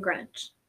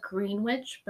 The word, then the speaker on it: Greenwich: Yes, because it happened in Greenwich. Which, by the way, if you ever look up this case, it's spelled Greenwich, but no one calls it Greenwich here.